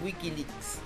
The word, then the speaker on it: WikiLeaks: WikiLeaks.